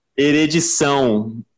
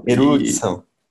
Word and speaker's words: erudição: Eredição. Eredição. – Erudição.